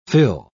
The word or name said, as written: fill